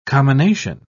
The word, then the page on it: commination: British English